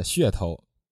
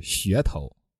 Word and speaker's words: 噱头: Karen，你刚才说商业的噱头，哈，其实它的正确发音是噱头。哇哈哈哈哈哈哈！ – 是噱头吗？